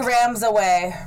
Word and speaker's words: Ramaswamy: Vivek Ramaswamy. – Ramaswamy.